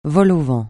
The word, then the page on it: vol-au-vent: francais